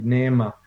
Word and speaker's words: Neema: NEEMA. You pronounce the E's separately, NEMA.